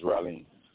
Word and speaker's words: Ralim: And my name is Raleen. R-A-L-I-M. – How do you spell that? – R-A-L-I-M.